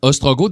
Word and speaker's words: Ostrogoth: En fait, ça s'écrit Ostrogoth, si vous voulez, ouais. là, O-S-T-R-O-G-O-T-H, puis ça fait référence au goth,